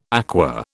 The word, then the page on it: aqua: British English